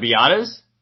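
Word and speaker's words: Biadasz: I already talked about Badiatis. Anybody else? – Badiatis? Tyler Biadas? – Oh, yeah. Sorry. I was saying his name wrong.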